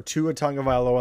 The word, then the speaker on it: Tagovailoa: So in your opinion, and Ryan, feel free to chime in as well, what's a successful season for Tua Tagovailoa and the Miami Dolphins this season? – Nailed the pronunciation, by the way.